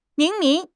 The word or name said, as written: 明明